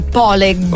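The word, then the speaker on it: pålegg: Allora, si scrive paleg con due g finali, a quanto pare si legge poleg.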